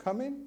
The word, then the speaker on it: cumin: "anise and cumin," would that be how you pronounce it? Cumin, okay, "and